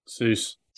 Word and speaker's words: Suess: Was it A, Alexander von Humboldt, B, Abraham Ortelius, C, Alfred Wegener, or D, Edward, how do you pronounce S-U-E-S-S? Seuss? Dr. Seuss? – Seuss.